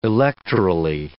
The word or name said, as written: electorally